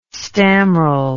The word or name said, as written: stammrel